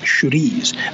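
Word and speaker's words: chouriço: If you want it spicy, use churis, which actually is spelled chorizo, but it's pronounced churis.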